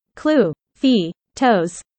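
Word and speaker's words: Clufetos: Repeat after me. Clufidos.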